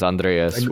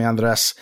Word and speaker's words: Andreas: And my name is Andreas, which. – Yeah. Which when I was in trouble growing up, my mom would call me Andres. So, really? – Which is just the Spanish pronunciation.